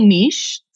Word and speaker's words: niche: It's actually niche. Sorry, cool. Americans. It's a French word. And so those that are French and those of us like Canadians and Australians that use the Queen's English, it's pronounced niche.